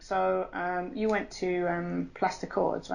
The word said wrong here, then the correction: Place des Cordes: right? – Yes, I did go to Place des Cordes in Paris. – You can say it with the actual proper pronunciation.